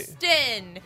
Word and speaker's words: Huston: Okay. Houston. Just like the fucking city. – H-U-S-T-O-N.